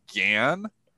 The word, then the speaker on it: GAN: They said Jan and not Gan. So the market thinks it's J-A-N. Meanwhile, it was actually G-A-N. So they confuse the soft G with the hard G.